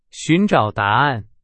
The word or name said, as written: 寻找